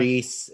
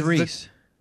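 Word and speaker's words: Rhys: Reese. It's R-H-Y-S. – Ugh, that's annoying, right? – I think that's Reese.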